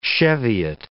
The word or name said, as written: Cheviot